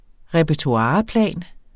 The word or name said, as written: repertoireplan